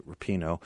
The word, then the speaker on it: Rapinoe: Uh, Megan Rapinoe, yeah, I'm saying that right, Rapinoe, women's soccer, um, women's uh, soccer champ is...